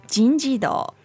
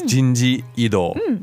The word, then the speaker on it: 人事異動: 人事異動. – So technically, it's 人事異動, but it gets sort of slopped together.